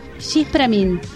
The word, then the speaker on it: Diz pra mim: Escuchamos a Breno Branch con su tema que yo diría que se pronuncia Gis Pramin. Gis Pramin. Es portugués.